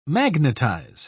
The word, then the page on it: magnetise: British English